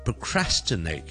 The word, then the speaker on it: procrastinate: 當我哋讀 procrastinate 嘅時候，個 crash 係重音，咁我之前個 pro 咧就比較少咧，就會讀成 pro 嘅，因為咁完整嘅音，我哋就將佢讀成係好輕嘅、好 hea 嘅 pr 就變咗 procrastinate。 procrastinate。